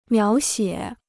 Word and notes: 描写: miáo xiě

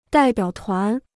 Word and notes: 代表团: dài biǎo tuán

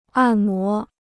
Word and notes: 按摩: àn mó

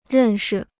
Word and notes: 认识: rèn shi

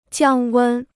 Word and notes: 降温: jiàng wēn

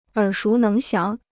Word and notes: 耳熟能详: ěr shú néng xiáng

